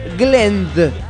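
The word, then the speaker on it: Glendh: Glend, porque se escribe con una H final, BBEM, o sea, BBEM.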